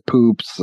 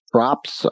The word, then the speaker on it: Proops: And you learn about like the Greg Proops Props. I can't say his name. – I think it's Proops. – Is it Proops? – I believe so, yeah.